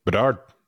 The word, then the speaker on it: Bedard: Bedard.